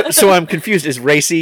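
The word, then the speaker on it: Raci: And yeah, how do you say his name? Paul Racy? – Mm-hmm. I believe so, yes.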